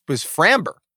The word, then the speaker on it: Framber: Important moment from tonight, Jordan, or not a moment, but was Framber.